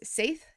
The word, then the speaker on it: Seidr: So today we kind of decided that we were going to talk a little bit about our experiences with Seidr, and I believe that you've also heard it pronounced as Seith. Is that correct? – Yes.